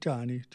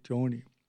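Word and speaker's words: Joanne: Joni, that's how you say it, not Johnny, Joni.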